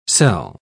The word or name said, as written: cell